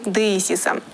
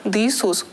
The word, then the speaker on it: деисис: Второй ярус – иконы деисиса. Слово «деисис» в переводе с греческого «моление». В русском быту это слово иногда искажается и произносится как «деисус».